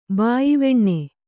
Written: ಬಾಯ್ವೆಣ್ಣೆ

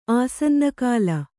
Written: ಆಸನ್ನಕಾಲ

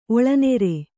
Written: ಒಳನಾರು

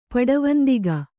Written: ಪಡವಂದಿಗ

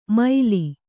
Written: ಮೈಲಿ

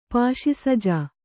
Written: ಪಾಶಿಸಜಾ

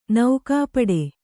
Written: ನಗುಪಾಡು

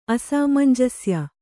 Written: ಅಸಾಮಂಜಸ್ಯ

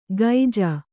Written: ಗೈಜ

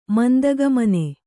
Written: ಮಂದಗಾಮಿನಿ

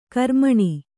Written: ಕಾರ್ಮಣ

